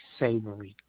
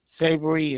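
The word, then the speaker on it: Savoury: Ricky Savory. – How do you spell that last name, sir? – Savory is S-A-V, as in Victor, O-U-R-Y.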